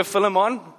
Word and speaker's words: Philemon: Have a look in the book of Philemon. I know I always get some flack for the way I pronounce this. If you prefer Philemon, amen. Philemon.